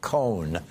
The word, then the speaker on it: Cohen: Now, right, and Trump even had Michael Cohen, who was his later lawyer after Roy Cohen died, had Michael Cohen start pronouncing his last name with that single syllable like Cohn, so that it sounded like he was Roy Cohn.